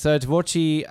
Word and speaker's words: Tvorchi: Is it Tvorchi? Tvorchi, am I saying that correctly, Jarrod? Or? – Yep, that's it.